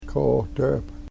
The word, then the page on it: kootep: Yurok